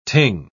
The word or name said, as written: ting